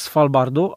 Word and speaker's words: Svalbard: Archipelag Svalbardu, a Spitsbergen, Spitsbergen, jak to się mówi? Archipelag Svalbard, tak jak mówisz, a Spitsbergen to jest y, najwyższa wyspa archipelagu.